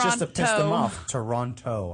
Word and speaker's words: Toronto: In Toronto. – In Toronto. Mm-hmm. – That's pretty cool. – I thought Canadians don't pronounce the second T.